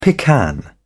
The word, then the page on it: pecan: British English